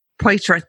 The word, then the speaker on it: Poitier: However, back in episode number 668 with Zach Poitier, I think I'm saying his name right. Poitier, thank you. See, it's a good thing there's no rolling of the R's there.